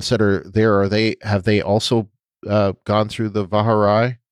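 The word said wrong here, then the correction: Vahar'ai: Emphasis on the second syllable.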